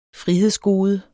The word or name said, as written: frihedsgode